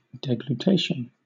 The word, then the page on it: deglutition: British English